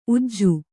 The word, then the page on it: ಊಜಿ: ūji